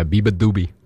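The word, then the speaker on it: beabadoobee: Up next, we have Apple Cider by Biba Doobie. – However you want to say your name, but that is how you say it.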